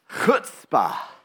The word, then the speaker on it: chutzpah: It's pronounced chutzpah. Why don't you turn to the person next to you and say chutzpah? If you're unsure, you pronounce it a bit like foot spa. But it's not just a ha, it's a chut spa.